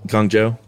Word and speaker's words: Guangzhou: I know I said it correctly the first time, but I looked it up recently, so just whatever I said the first time. I'm pretty sure it was Guangzhou.